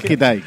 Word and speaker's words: Kitai: es Kitai, Kitai.